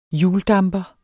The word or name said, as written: HJULDAMPER